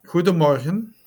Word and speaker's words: Guten Morgen: Guten Morgen.